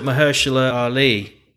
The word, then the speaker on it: Mahershala Ali: No, it's got Mahershala Ali.